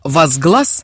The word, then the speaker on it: возглас: Слово «возглас», по церковной традиции, употребляемое с ударением на последнем слоге, означает «возвышение голоса».